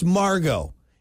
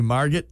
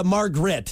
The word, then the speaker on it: Margot: What did you say, Margot? – I said Margaret. So, again, I saw the T, and my brain switched it into Margaret. Like a moron. – The T is silent.